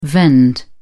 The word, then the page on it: vend: British English